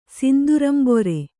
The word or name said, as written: ಸಿಂದುರಂಬೊರೆ